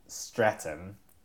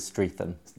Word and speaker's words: Streatham: We're in my flat in Streatham, which has a ham at the end, although you don't really pronounce the H. – Easily mispronounced as Streatham. – That is how I pronounced it Streatham. When I first came here, and I was ruthlessly mocked for it.